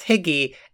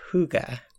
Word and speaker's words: hygge: Often mispronounced Higgy, it's actually more like Hygge.